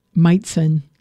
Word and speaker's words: Majcen: Oh, Meitzen. Leon Meitzen. I did it. Leon Meitzen, but it's spelled M-A-J-C-E-N.